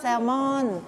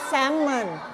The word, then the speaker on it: salmon: salmon แต่ภาษาอังกฤษอ่านว่า salmon ไม่ออกเสียง l นะ salmon